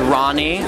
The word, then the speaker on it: Rani: So my real name is Ronnie, R-A-N-I.